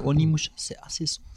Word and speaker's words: Onimusha: Euh, ah, j'arrive jamais à le prononcer ce nom. Omni Nusha. tu, Onimusha. Omni, voilà.